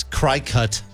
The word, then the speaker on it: Cricut: C-R-I-C-U-K. – It's Cricut. – C-R-I-C-U-K. – Oh, C-U-T.